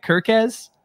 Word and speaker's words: Kerkez: How do you say it? – Kerkez.